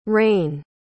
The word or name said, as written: reyn